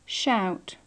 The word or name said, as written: shout